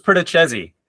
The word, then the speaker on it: Perdichizzi: Joining us today is Drew, hopefully I say your name right, Perdichizzi? – Close, Perdichizzi. – Perdichizzi, okay.